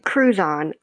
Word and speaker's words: Crouzon: Crouzon. I always tell people it's like crouton, but with a Z.